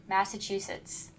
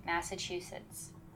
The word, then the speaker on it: Massachusetts: Massachusetts. I said it right. – Yeah, you did. Good job.